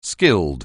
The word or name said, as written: skilled